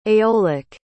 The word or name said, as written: aeolic